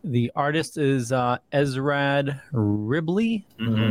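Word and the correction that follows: Esad Ribić: Are you talking about the artist for this, Asad Ribic? – Yes.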